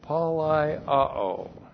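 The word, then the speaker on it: palaioō: That's P-A-L-A-I-O, long O, palaiao.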